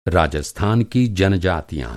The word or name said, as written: जनजाति